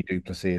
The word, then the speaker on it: Du Plessis: But I would say if he was French, he would be how he pronounced Dreyfus, but he would be Du Plessis. And he's definitely Du Plessis as a South African.